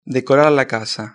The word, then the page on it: decorar la casa: European Spanish